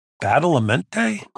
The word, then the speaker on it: Badalamenti: Kyle MacLachlan playing an FBI agent that liked cherry pie, and a very eerie soundtrack by Angelo Badalamenti. Did I say that right? Anyhow, said it right for me.